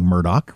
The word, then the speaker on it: Murdaugh: In the courtroom, they're saying Murdoch. Why wouldn't you go with what they're saying in the courtroom? – That's how the family pronounces it.